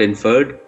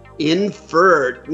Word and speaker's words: INFRRD: Your company is called Inferred, I-N-F-R-R-D, no vowels. So you kind of leave that up to our imagination, but how do you pronounce it? – Yeah, it's called Inferred.